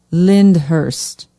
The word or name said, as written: lyndhurst